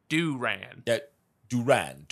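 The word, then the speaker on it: Duran Duran: Of course, the name was taken from the movie Barbarella, so you could assume the correct pronunciation would be D-E-W-R-A-N. Duran. – Said Duran, like Duran. – A Southern Baptist. Duran. – Duran, Duran.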